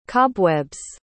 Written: cobweb's

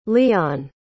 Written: leon

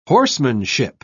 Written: horsemanship

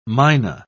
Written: myna

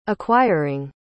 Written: acquiring

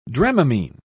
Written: dramamine

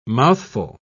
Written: mouthful

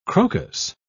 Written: crocus

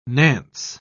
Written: nance

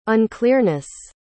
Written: unclearness

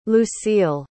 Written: lucille

